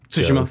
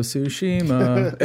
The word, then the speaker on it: Tsushima: Do me a favor, though, and please stop pronouncing it so incorrectly. It's pronounced Tsushima. – So Tsushima.